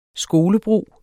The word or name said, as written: skolebrug